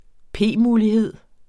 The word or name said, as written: p-mulighed